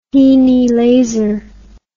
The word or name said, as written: henelaser